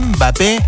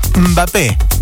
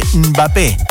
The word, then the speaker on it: Mbappé: En vez de Mbappé, sacale la E, Mbappé, ¿sí? Es así, Mbappé, con la lengua en el paladar.